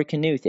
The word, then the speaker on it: Knuth: Is it Knuth or Knuth? Is the K silent? It's Knuth. It's not silent.